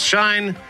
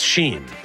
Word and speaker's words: SHEIN: But I'd never even heard of this company, S-H-E-I-N. – Yes. – I thought it was Shine. It's Sheen.